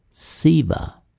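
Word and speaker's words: SEVA: This was termed stand-up EVA, pronounced SIVA, and was tentatively designated the primary shade for deployment.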